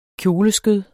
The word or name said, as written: kjoleskød